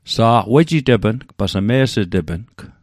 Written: wejitepng

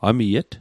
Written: amiet

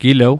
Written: gilew

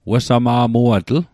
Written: wesama'muatl

